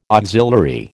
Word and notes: auxiliary: American English

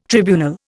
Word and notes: tribunal: Indian

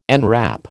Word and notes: enwrap: American English

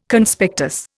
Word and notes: Conspectus: Indian

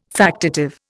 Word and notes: factitive: Indian

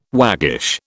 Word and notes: waggish: British English

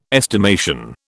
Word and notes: estimation: British English

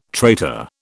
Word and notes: traitor: British English

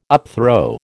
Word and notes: upthrow: American English

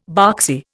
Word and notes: boxy: Indian

